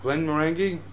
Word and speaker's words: Glenmorangie: Okay, if I'm pronouncing this correctly, but Glenmorangie. Morangie, or it's a hard G or soft G? – No, that's right.